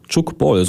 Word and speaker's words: Chukball: Chukball, so muss man es aussprechen, denn die Sportart kommt aus der französischsprachigen Schweiz aus den 70er Jahren.